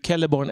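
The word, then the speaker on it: Celeborn: Eh, exakt, och det är alltså samma alltså i Quenya och även i Sindarin faktiskt att bokstaven C uttalas som K. Och det är därför vi får, nu är ju Kelleborn är ju Sindarin men det är ett tydligt exempel.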